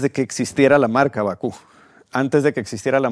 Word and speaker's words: Baqú: ¿Bakú se pronuncia? – Bakú, sí. sí.